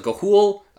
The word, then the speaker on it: Ga'Hoole: No, Ga'Hoole? – Yes. – My brain just says Galooly and I move on with my life. – Yeah, the guy that makes glass over in Seattle, Ga'Hoole.